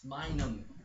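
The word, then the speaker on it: Minun: Oh, he hasn't seen Pikachu or Pichu or Minos. – It's Minum. That's how you pronounce it.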